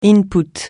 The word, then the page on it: input: francais